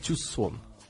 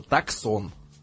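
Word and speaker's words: Tucson: Значит, у Hyundai есть такой джип, они его называют Тюсон. Пишется Таксон. Туксон, Таксон.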